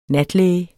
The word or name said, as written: natlæge